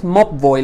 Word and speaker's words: Mobvoi: Es Mobvoi.